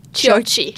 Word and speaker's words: Chiocchi: Bannister and, now I always say this wrong, is it Chiocchi? – Chiocchi. – Chiocchi with the H.